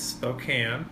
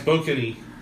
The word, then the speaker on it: Spokane: Like, Spokane. – Yeah. Well, and not Spokane either. – Spoken? – No, I was trying to give Casey a hard time for his first syllable emphasis.